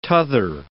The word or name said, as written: t'other